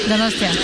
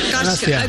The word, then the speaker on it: Donostia: Donostia. – Donostia. – Donostia.